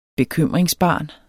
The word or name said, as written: bekymringsbarn